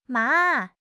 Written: ma3

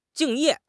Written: 敬业

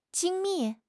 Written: 精密

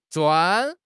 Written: 砖

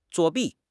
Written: 作弊

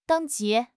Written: 等级

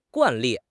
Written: 惯例

